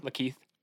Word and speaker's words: Lakeith: Lakeith.